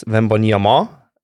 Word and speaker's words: Wembanyama: Like Wembanyama, but probably in uh, because it's French, the uh, emphasis is on the on on the last a, so it's vemboniama instead of vemboniama But people are getting used to saying vemboniama or Vembi, and that's fine.